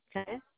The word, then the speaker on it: Chaya: C-H-A-Y-A. – C-H-A-Y-A. Kaya? – Mm-hmm. – Or is it silent C? So Kaya? – No. It's perfect. It's Kaya. – Kaya?